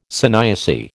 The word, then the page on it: sanyasi: American English